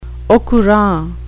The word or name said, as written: au courant